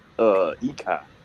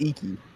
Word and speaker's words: Iki: I've been saying Phoenix Ikai. – No, it's pronounced Iki. It's a Japanese. It's a Japanese word. It means like gleam.